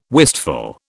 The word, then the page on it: wistful: British English